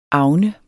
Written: agn

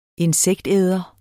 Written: insektæder

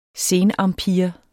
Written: senempire

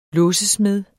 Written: låsesmed

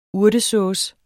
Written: urtesauce

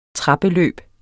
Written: trappeløb

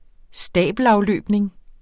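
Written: stabelafløbning

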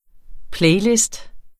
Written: playliste

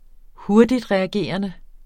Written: hurtigtreagerende

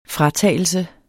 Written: fratagelse

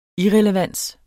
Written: irrelevans

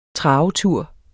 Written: travetur

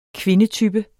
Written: kvindetype

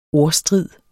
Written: ordstrid

